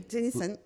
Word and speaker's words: Jenyssone: No, Jenyssone.